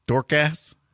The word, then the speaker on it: Dorcas: D-O-R-C-A-S. Am I pronouncing that right? Dorcas? Dorcas? – D-A-R. – D-O-R-C-A-S. Dorcas. – Yeah, I believe that's correct.